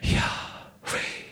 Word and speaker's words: Yahweh: In fact, the Hebrew word Yahweh, apparently the way that the Hebrews would pronounce it, and they wouldn't because it's the holy name of God. But if you would pronounce it, it would be more like taking a breath.